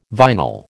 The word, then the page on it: vinyl: American English